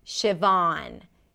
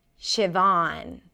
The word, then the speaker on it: Siobhan: A V sound, ah, as in father, or you can even round the lips a little bit, and then an N. Vaughn. Siobhan. Siobhan.